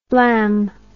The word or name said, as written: blam